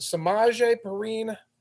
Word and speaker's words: Samaje Perine: The other thing is, and I don't want to butcher his first name, is it Samaje Perine of the Bengals? Yeah. – Sounds correct.